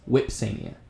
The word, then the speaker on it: Vipsania: But in Latin, Vs are pronounced as Ws. So Vipsania becomes Whipsania.